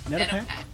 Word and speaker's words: Nedopak: Nedopak.